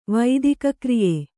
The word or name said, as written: ವೈದಿಕಕ್ರಿಯೆ